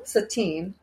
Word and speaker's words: Satine: Satine, which is how Satan is pronounced in South Park.